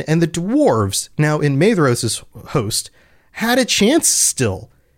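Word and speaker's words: Maedhros: And in the confusion that they wrought, they came near to the standard of Madros. And I just realized, I've been doing it again. Pronunciation is rough. Madros, it's a DH. I talked about this on the last episode, and yet I forgot. Madros, by the standard of Madros.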